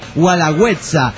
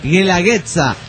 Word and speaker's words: Guelaguetza: Supongo que todos los oaxacos nos encabronamos cuando dijiste Gualagüetza. Dice, no se pronuncia Gualagüetza, sino Guelaguetza. Dice, es, güey. Dice, como guerra.